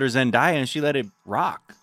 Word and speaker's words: Zendaya: From Johnny Davis, we just learned it is not pronounced as papaya, like Zendaya. It is Zendaya or Trendaya.